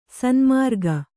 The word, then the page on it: ಸನ್ಮಾರ್ಗಿ: sanmārgi